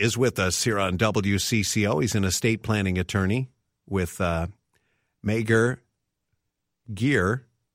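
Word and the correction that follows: Meagher & Geer: Did I say that correctly, Tim? – You didn't. It's Mayor and Gear. – Mayor and Gear.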